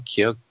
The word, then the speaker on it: Keough: Uh, um, Love Song has Riley Keough. Is that how you say her name? Keogh. I think you just Keogh. You don't have to worry about the G.